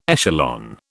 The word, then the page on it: echelon: British English